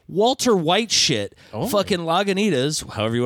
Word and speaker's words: Lagunitas: Lagunitas. – Is that how you say it? – Uh-huh. – I say Lagunitas.